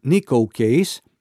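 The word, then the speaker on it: Neko Case: Αυτό είναι το Destination, που μόλις παραθέσαμε δεύτερο στην πεζολίστα μας, με την Nico Case. Λέγεται Nico, αλλά προφέρεται, γράφεται N-E-K-O.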